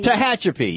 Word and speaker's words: Tehachapi: Tehachapi.